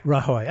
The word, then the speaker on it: Rajoy: Just a few days later, as we mentioned, the largest country in the Iberian Peninsula, Spain, held parliamentary elections while Prime Minister, I'm not sure if I pronounced this right, Rajoy's center-right... – Rajoy. – Yeah, it was one of the other.